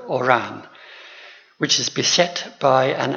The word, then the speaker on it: Oran: One is the name of a town that's going to be mentioned, O-R-A-N, which I shall call Oran. In French, it might be Oran. And there's a gentleman mentioned in the text, a civil servant, whose name is G-R-A-N-D.